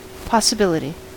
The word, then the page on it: possibility: American English